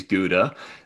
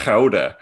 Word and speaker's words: Gouda: So everyone in America is like, oh my God, I love that Dutch cheese, Gouda. It's actually pronounced Gouda. Gouda, they say that like that.